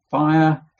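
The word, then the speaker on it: Apphia: And it's possible, though it's no more than conjecture, that Fire, that's how you pronounce her name, was his wife, and maybe Archippus was his son.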